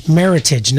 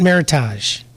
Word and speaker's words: Meritage: Meritage, not Meritage.